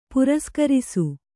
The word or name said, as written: ಪುರಸ್ಕರಿಸು